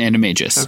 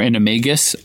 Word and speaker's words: animagus: She's an animagus. – Okay. – Or animagus. I've heard it pronounced both ways.